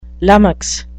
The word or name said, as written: lummox